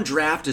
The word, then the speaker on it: draught: The noun draught is pronounced exactly like draft, just like a draft beer, right?